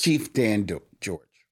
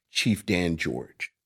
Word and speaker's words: Chief Dan George: Chief Dan George. – Chief Dan George.